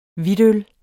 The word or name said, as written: hvidtøl